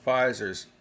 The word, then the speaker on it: Pfizer: Another little honey of a bullet point, Pfizer CEO, and by the way, Pfizer's like got that silent P like Psaki. It's Pfizer. Pfizer CEO Albert Bourla,